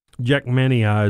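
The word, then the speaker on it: Jackmanii: How do you say that? – Jackmania is the correct way.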